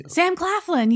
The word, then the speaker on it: Claflin: Sam Claflin.